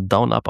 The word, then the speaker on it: DNUP: Das ist auch schon ein bisschen länger draußen, aber ich kann es noch nicht, nämlich Down Up. Von Asmodee. – DNUP, ne? – Genau, geschrieben wird es DNUP. Haben mir sagen lassen, dass man es Down Up aussprechen soll.